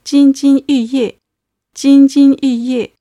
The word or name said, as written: jinjin yuye